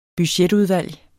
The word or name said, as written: BUDGETTER